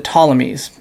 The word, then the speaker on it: Ptolemies: And so at first, one of those four kingdoms was the kingdom that ruled over the land of Israel, and that was the Ptolemies, with a silent P at the beginning there.